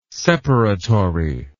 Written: separatory

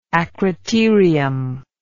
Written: acroterial

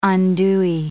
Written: andouille

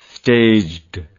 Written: staged

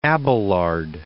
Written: Abelard

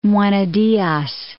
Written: Juana Diaz

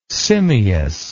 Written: simious

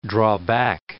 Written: draw back

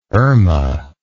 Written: Erma

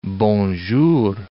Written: bonjour

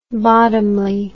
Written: bottomley

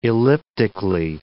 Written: elliptically

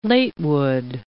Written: latewood

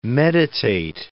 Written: meditate

